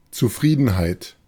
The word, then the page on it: Zufriedenheit: [tsuˈfʁiːdn̩haɪ̯t]; [tsuˈfʁiːdənhaɪ̯t]; /ʦuː'fʁiːdənˌhaɪ̯t/